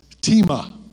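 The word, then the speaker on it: tima (τιμή): Time is the word, but it's pronounced tima. Spelled time, pronounced tima.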